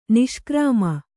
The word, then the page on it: ನಿಷ್ಕ್ರಾಮ: kannada